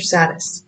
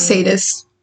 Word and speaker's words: sadist: How do you say, is it sadist or saddest? – I thought it was sadist. – Okay, sadist, 42%.